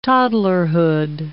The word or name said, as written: toddlerhood